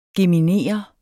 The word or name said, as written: GEMINER